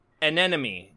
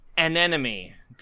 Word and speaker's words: anemone: Okay, I got one here, and this one's a mouthful. And took me like three or four times to pronounce it correctly here. It's anemone girl. All right? – Repeat that. – Anemone girl. You know, A-N-E-M-O-N-E. – Oh.